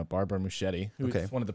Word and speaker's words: Muschietti: I say Muschietti. I think Pat puts a weird emphasis on the I. – The Muschietti. – Muschietti. – Muschietti. – No, it's more American. He says Muschietti.